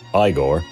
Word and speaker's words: Igor: The beautiful Inga, his inconsistently hunchbacked sidekick, Igor, pronounced Igor, and of course, Frau Blücher.